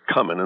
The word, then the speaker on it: cumin: Indicating that the caller was just fine with your pronunciation of cumin. But you should know that if you look up in a dictionary, you'll find that the that is apparently a brand new pronunciation going around. The traditional pronunciation is cumin. In fact, sometimes has been spelled with two M's.